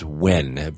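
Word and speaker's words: Huygens: And it's called the, it's pronounced WHEN, but it's H-U-Y-G-E-N-S, down to Titan's surface.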